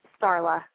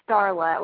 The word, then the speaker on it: Starla: Starla. – Oh, you've got a bit of a shaky phone line there. We want to make sure we say your name right. Is it Darla or Carla? – Starla with an S T.